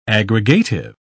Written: aggregative

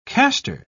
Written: castor

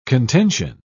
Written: contention

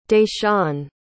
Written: dayshaun

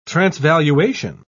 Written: transvaluation

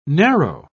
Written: narrow

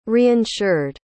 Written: reinsured

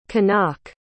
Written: Kanak

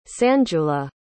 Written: sanjula

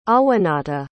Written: awanata

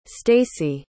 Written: stacy

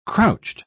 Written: crouched

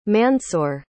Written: Mansoor

